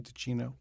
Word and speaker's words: Ticino: But Ticino, is that, how do you pronounce the original, the part of Switzerland where the Delmonico brothers were from? Ticino? – Ticino. – Yeah. See, yes. I was just in the Italian part of Switzerland.